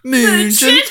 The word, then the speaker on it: München: Munchen? – To win. Born in Munchen. First in 64 years to win the Olympic marathon. – I'm sure I'm even saying that wrong anyway. It's actually München.